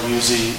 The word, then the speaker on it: Uzzi: My name is Gerard Uzi. The last name is spelled U-Z-Z-I.